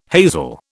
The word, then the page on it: hazel: British English